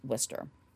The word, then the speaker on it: Worcester: And if you are looking this up, Worcester looks like it's spelled like Worcester without the H, but it is pronounced Worcester.